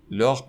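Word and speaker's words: leurs: لاغ پران. اینجا هر سه تا تلفظ یکی هست ولی در نوشتار با هم متفاوته یعنی لاغ در حالت مفرد اس نداره ولی در حالت جمع اس داره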